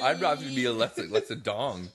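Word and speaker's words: Lexa Doig: Is that how you say her name? – Yeah, Lexa Dong. – Like as in dong? I know it's D-O-I-G. – No, it's Lexa Dong.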